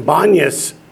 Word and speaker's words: Banias: And Banias is actually Panias, but the Arabs can't pronounce the P, so they call it Banias.